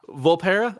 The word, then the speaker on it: Volpera: I think I saw a Volpera, is that how do you say that, little fox people? – Volpera.